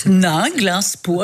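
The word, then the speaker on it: Ninglinspo: C'est écrit comme ça se prononce. N-I-N. – Absolument. Nainglinspo, avec O au bout.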